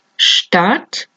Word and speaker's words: Stadt: ST es como una sh, o sea, como SH en inglés y una T detrás. Stuhl, Stadt, Stift, stehen.